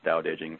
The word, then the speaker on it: Tao Te Ching: It's spelled Tao Te Ching, pronounced Tao Te Ching for complicated reasons that we're not going to discuss tonight.